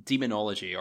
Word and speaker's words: Daemonology: it's called Demonology, or Daemonology. I think it's spelled D-A-E-M. It's probably... probably demonology.